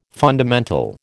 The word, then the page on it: fundamental: American English